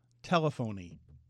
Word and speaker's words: telephony: And it's pronounced telephony. – It is telephony. because I looked at it, telephony. – That. – That's fine. Telephony. – Telephony. – Okay, which doesn't sound like telephone.